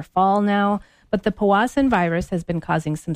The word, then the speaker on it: Powassan: Yes, and you're saying it right. – Powassan.